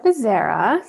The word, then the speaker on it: Bezerra: My name is Nora Bezerra. So close. It was beautiful, your pronunciation, though. Maybe in an alternate life, that'll be the pronunciation.